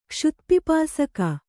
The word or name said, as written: ಕ್ಷುತ್ಪಿಪಾಸಕ